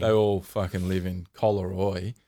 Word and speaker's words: Collaroy: So he's got a property in Collaroy. – Is that how you say it? Collaroy. – Yeah, how did you think you said it? – Collaroy. I put the emphasis on the co.